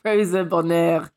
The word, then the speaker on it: Rosa Bonheur: Rosa Bonheur.